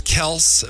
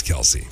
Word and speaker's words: Kelce: It's pronounced Kels, but everybody says Kelce.